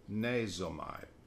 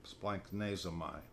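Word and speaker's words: splanchnizomai: Compassion sometimes translated bowels, but it's the word that's hard to pronounce. It's splanknezomai, splanknezomai, splanknezomai.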